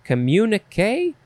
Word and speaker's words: communiqué: Communiqué? Communiqué. How many times can you say it? It is spelled C-O-M-M-U-N-I-Q-U-E, and there is an accent on the E, low on the left and high on the right.